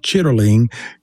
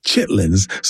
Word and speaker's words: chitterling: It was part of what they called the, even though the word is more accurately pronounced, chitterling, we say chitlins.